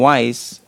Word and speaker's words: Weiss: So, Matt from Mutti, נקרא לי Matt, business Matt, and Weiss, but in English is spelled W-E-I-S-S.